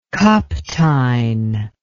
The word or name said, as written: Kapteyn